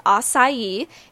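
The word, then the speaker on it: açaí: And also, is acai actually the right way to pronounce it? So acai, and yes, I looked it up, it is pronounced as acai, is a fairly new food trend in the United States, but it's actually been eaten for thousands of years.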